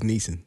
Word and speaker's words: Neeson: I thought it was Neeson.